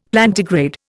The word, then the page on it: Plantigrade: Indian